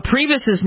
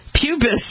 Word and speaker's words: Priebus: He pronounced Priebus's name Pubis.